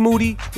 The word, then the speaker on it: Moody: G Moody, last name rhymes with duty.